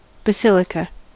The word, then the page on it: basilica: American English